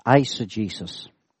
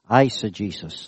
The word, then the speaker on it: eisegesis: This word is pronounced eisegesis. Okay, I'd like to say that word. Eisegesis, that's how it's pronounced.